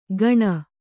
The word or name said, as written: ಗಣ